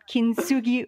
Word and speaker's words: Kintsugi: The T is silent. Kintsugi Wellness, which is based on her untold story of her heritage.